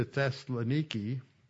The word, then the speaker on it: Thessaloniki: Now, if you think back to when you were reading through Acts chapter 17, and Paul goes to Thessaloniki, which is the Greek pronunciation, he goes to Thessaloniki, and Paul and Silas are with him.